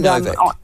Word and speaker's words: Nowak: I just Novak or it's Nowak, yes, but they pronounce it Novak, right. Is that right? – Yes. Yeah. That's right. – Henry Novak.